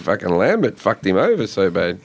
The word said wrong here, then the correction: Lampere: You still get his name wrong. – Yeah. – Why am I saying that wrong? – Lampere. – Oh, God. – It's French.